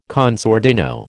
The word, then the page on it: con sordino: American English